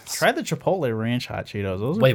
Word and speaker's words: Chipotle: Chipotle. The L comes after the T.